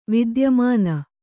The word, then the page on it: ವಿದ್ಯಮಾನ: vidyamāna